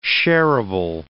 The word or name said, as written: shareable